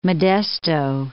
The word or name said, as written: Modesto